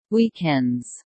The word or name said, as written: weekends